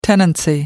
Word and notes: tenancy: American English

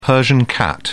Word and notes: persian cat: British English

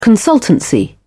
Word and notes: consultancy: British English

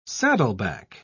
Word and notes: saddleback: British English

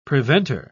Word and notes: preventer: British English

